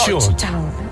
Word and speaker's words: Georgetown: No podía ser otra que El exorcismo de Georgetown. ¿Lo he dicho bien? ¡Qué bien dicho! – Georgetown.